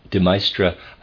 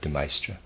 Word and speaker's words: De Maistre: De Maistre, I am pronouncing it that way, is D-E, then capital M-A-I-S-T-R-E. Forgive me, I'm going to pronounce that De Maistre.